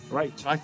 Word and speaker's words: Reich: Reich.